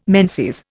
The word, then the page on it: menses: Indian